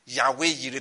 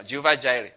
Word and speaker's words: Yahweh Yireh: When Abraham said Jehovah Yireh, that is, like they say, Yahweh Yireh, that's how they say it now, Jehovah Jireh, okay?